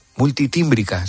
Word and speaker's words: multitímbrica: ¿Qué es eso de la gaita electrónica, a ver si lo digo bien, multilímbrica? – Multitímbrica. – Sería. Multitímbrica.